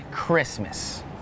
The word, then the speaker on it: Christmas: Some examples with a silent T are the words listen, the word castle, and the word Christmas.